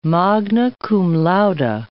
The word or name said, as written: magna cum laude